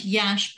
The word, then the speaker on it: Iași: Until I actually was in Iași, which is pronounced Yash but spelled IASI, for those of you who Yes.